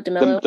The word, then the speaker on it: DeMelo: Um, how do you say his name? Dylan DeMelo. – DeMelo. DeMelo. – DeMelo. Yeah.